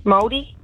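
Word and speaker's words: Māori: Maori? – Oh. And you pronounced it beautifully. It's English.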